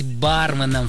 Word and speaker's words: барменом: Несмотря на ожесточенные споры вокруг данного слова, лингвисты утверждают, что верным является только один вариант с первым ударным слогом. Тем более, что заимствовано оно из английского, где тоже произносится с акцентом на букву А. Короче говоря, правильно говорить барменом, Фаина.